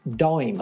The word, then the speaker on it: Daim: Out of, I think he's from Denmark, and his 3D letter forms, which were Dame, D-A-I-M. – Sorry, Dame. – Well, actually, no, that's pronounced Dime. – Oh, what, like Dime Bar? – Yeah.